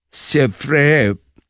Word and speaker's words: Zverev: También el alemán Sheffield, déjeme decirle, yo digo, se escribe Zverev, pero yo, para estar bien seguro, busqué en las redes cómo se pronunciaba, y entonces la pronunciación es así: Alexander Zverev le ganó a Ben Shelton, y este partido duró casi 4 horas.